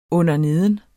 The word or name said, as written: underneden